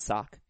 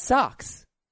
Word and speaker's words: Red Sox: Boston Red Sox? Not sock. – He's a sock. – I think it's socks.